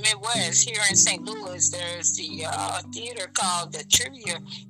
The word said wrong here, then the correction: Tivoli: Wow, we say the name wrong. – Tivoli? – Tivoli, there you go.